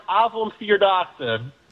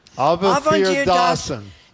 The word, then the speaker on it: Avondvierdaagse: It's pronounced Avondvierdaagse. – Avondvierdaagse.